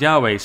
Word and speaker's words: Yahweh: and he said that that people thought that was Jehovah, and he goes, but that's a mistake because it's actually pronounced Yahweh.